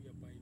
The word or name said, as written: apai